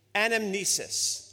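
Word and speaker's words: anamnesis (ἀνάμνησις): In Greek, the word for remembrance is pronounced like this, anamnesis. Let me say that word again. Anamnesis.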